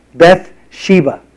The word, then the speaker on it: Bathsheba: The name Bathsheba, and it's not Bath. It's unusual that her name is associated with taking a bath, and it's in the Bible. It's actually Beth. Bathsheba is the way you would pronounce that.